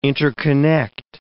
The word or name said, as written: interconnect